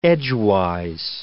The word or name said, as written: edgewise